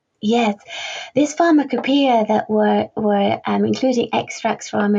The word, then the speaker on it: Pharmacopoeia: So also in your book, you've got extracts of historical publications, including the Edinburgh, and I apologize if I say this wrong, Pharmacopoeia? – Oh, very well pronounced.